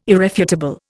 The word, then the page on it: irrefutable: Indian